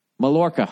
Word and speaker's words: Mallorca: How to pronounce Mallorca. Mallorca. Come on, say it. Say it. Say it. – Mallorca. – That's it? – Mallorca. – Oh, Mallorca? There's two L's in it.